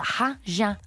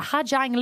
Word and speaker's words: Ha Giang: And I say it properly because at first I told people in the airport where we were going, and I was like, the Ha Giang Loop. And he was actually like, what?